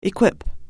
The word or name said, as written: equip